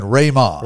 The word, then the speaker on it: rhema: I've I've learned a lot, especially about the point that you brought up there earlier in our podcast about uh, is it rhema um, the best I can tell pronunciation, Rayma. Rayma. Yeah. – Rayma, certainly. So